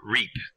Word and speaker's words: Ryp: Yeah, Rip is a... Or Reap. I keep wanting to do Rip, but the correct pronunciation is Reap.